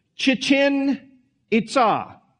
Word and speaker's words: Chichen Itza: But it's a place called, I'll do the best I can to pronounce it, Chichen Itza. That's how they said to pronounce it. You put the emphasis on the last part of the word.